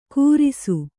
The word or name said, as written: ಕೂರಿಸು